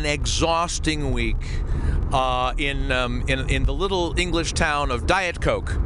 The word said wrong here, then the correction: Didcot: No, what's it called? What was it called? – Didcot. – Excuse me, Didcot. Americans never know how to pronounce English towns.